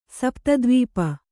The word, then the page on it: ಸಪ್ತದ್ವೀಪ: sapta dvīpa